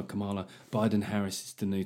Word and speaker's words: Kamala: Well, let me tell you, like, his biggest problem, him and Kamala Harris, because apparently Brits aren't saying it right. We've got Kamala Harris. Kamala. It says Kamala, for fuck's sake.